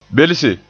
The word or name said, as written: belse